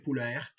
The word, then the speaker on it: Poulaert: Donc Michel Poulaert, ça s'écrit P-O-U-L-A-E-R-T, à L-A-E-R-T, Poulaert,